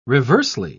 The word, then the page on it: reversely: British English